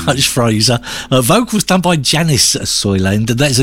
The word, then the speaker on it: Janus: And Fraser tells us that Janice is how it's pronounced, even though it's spelt J-A-N-U-S.